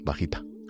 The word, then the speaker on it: Vaquita: ¿Se pronuncia así? Vaquita. – Oui.